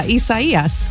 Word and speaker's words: Isaias: No, well, it is Isaiah, but in Spanish. – Right. – So, I mean, even Governor Ron DeSantis gave up. I think he's calling it Isaiah. He's just like, I'm going to butcher it every time. So he gave up. But yeah, Isaias.